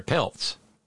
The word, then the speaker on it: Peltz: Putz, or Peltz. Of course, not here, down in Florida. – Nelson Putz? – Peltz. Sorry.